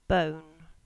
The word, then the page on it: Bone: English